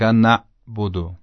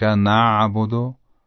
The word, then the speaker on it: نعبد: تلفظ غلطش رو اول انجام میدم بعد تلفظ صحیحش رو. ایاک نعبد، این غلطه. و اما ایاک نعبد، ایاک نعبد، این صحیح و درسته.